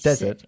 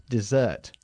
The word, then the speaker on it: dessert: A dessert, not desert. A desert okay. – es el desierto, ¿ok? A dessert es un postre, ¿ok? Enfatizando la segunda sílaba, dessert.